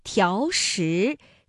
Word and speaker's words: 条石: 那本来两个相连呢还应该是两个三五啊，但是呢考虑到连读需要变调，那么第一个字就读成了三四，第二个依旧读三五，比如条石。